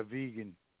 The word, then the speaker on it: Vaughan: How do you say that last name? Vagan, V-A-U-G-H-A-N? – Yeah, vegan.